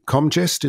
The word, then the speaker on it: Comgest: And you were talking to the Comgest. Is that how you pronounce it? The Comgest people? – Right. Yeah. Yep, that's right.